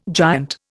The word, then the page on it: giant: Indian